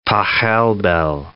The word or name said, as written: Pachelbel